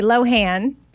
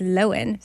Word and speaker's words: Lohan: It's Lindsay Lohan. And guess what? Now I'm on TikTok. – So we say Lohan, but yeah, it's Lohan.